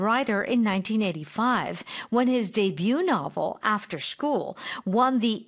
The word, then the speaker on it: debut: Okay, debut, of course, is French for first.